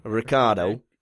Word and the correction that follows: Ricciardo: Ricciardo. Ricciardo quoted yesterday saying he expected to run well in Bahrain.